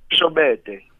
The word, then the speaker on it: Shobede: Shobede.